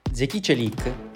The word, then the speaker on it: Zeki Çelik: Zeki Çelik, questa la pronuncia corretta del suo nome, si è presentato oggi pomeriggio in conferenza stampa.